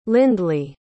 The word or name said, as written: lindley